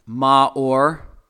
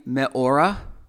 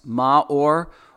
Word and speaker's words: ma'or: So lights, Strong's Concordance number 3974 is the word ma'or. It can also mean me'ora. And it's spelled a few different ways, but those are the 2 pronunciations, ma'or or me'ora.